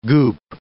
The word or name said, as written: goop